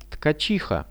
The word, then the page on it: tkachiha: Russian